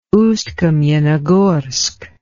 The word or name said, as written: Ust-Kamenogorsk